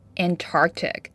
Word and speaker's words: Antarctic: The stress is on the second syllable, Antarctic.